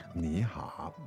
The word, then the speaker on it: 你好: 你好。你好 Meaning hello. – Hello. – There you go. 你好 Two third tones, right? – That's right. – 你好 There you go.